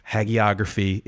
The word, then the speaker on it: hagiography: Hagiography is in fact pronounced with a hard G.